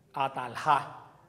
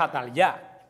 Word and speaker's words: Atalía: Atalja, no Atalya. Hubiera sido Atalja, pero el nombre es Atalya, porque quiere decir Yahvé manifestó. Por eso los judíos no tenían el nombre, la pronunciación J. Lo pronunciaban casi como la Y de nosotros.